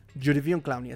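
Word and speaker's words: Jadeveon Clowney: Jodevion Clowney. Jodivion Clowney, así se pronuncia mucho.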